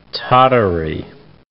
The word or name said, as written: Tatary